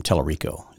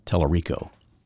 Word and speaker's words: Talarico: Talarico is how you pronounce it. Talarco. Talarico. It's Spanish, I think, something, or maybe... Maybe Italian, but I think Dan Patrick went across the line there a little bit.